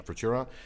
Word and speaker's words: Futura: So the 55 Lincoln Futura, is that how you say it? – Yep. – Yeah, Futura.